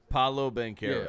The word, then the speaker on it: Paolo Banchero: Paolo Banchero.